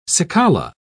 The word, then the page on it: cicala: British English